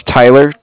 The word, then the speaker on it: Tyler: My name is Tyler, T-Y-L-E-R. I'm calling with Cardiff. It's spelled C-A-R-D-I-F-F, as in Frank, Frank.